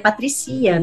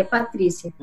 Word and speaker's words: Patrycya: Falou, nossa, é não pode aceitar um, um nome assim, porque isso aqui é um erro gramatical, que é dois Y numa palavra, você perde a sílaba tônica. Então, meu nome seria Patricia, não seria Patrícia.